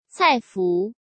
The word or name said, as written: Cai Fu